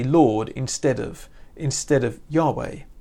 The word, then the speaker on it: Yahweh: You might notice that as you read it, that actually that is a special name of God, which we would normally pronounce Yahweh.